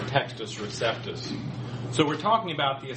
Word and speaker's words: Textus Receptus: In classical Latin, that C would be pronounced like a K, so Textus Receptus. But it's usually anglicized to say Textus Receptus.